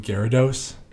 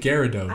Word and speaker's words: Gyarados: I I always pronounced it Gyarados. – I thought it was Guy because it's G Y R A D O S.